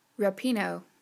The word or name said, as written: Rapinoe